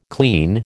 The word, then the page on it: clean: American English